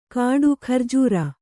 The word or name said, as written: ಘೂರ್ಜರಿ